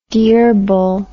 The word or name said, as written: dyirbal